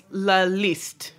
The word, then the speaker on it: La Liste: Or I don't know if it's La, L-I-S-T-E. It's not pronounced. Is it La Liste? – I think it's La Liste. – La Liste. – Yes, I think so. – It's French.